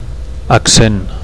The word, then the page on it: accent: Catalan